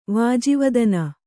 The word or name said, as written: ವಾಜಿವದನ